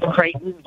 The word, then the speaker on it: Creighton: Yes, Creighton, like Creighton University in Indiana, actually. Spelled that way, too.